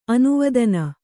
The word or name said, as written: ಅನವಧಾನ